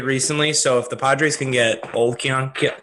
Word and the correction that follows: Keone: Keller back, I like that signing a lot for them. – Keone Keller. – Keone. Pronunciation is not my strong suit.